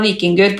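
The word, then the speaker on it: Víkingur: Eh, men la meg høre deg si hva, hvordan skal det lagnavnet deres egentlig uttales? – Vortjingur. – Ja, ikke sant? Men vikingur er også fint.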